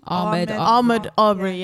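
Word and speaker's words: Ahmaud Arbery: Ahmed Aubrey.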